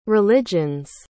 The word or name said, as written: religion's